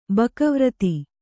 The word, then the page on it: ಬಕವ್ರತಿ: baka vrati